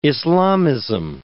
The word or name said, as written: Islamism